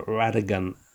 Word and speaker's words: Rodigan: And the guy who wrote it, the ghostwriter, replied to say something that David Rodigan, or Radigan, as I believe he pronounces it, has been given the Jamaican Order of Merit or something? – Yeah.